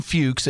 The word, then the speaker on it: Fuchs: So we got Jen Fuchs. It's spelled F-U-C-H-S, so don't be dirty out there, y'all.